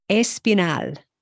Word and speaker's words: Espinal: And I picked these because in most of these words, unless it's noted, the accent falls on the final syllable, which is not intuitive for English speakers. So let's look at this list. Amenal. – Amenal. – Espinal.